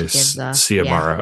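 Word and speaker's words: Xiomara: Publishers Weekly said debut novelist Acevedo's free verse gives Ziamara, how do you pronounce the main character's name? I want to say Ziamara, but I'm not. – Ziamara? Okay. Yeah, because it's on, well, one of her poems goes through that pronunciation. – Okay, Ziamara. Yeah. Okay, all right. Yeah. So I was correct.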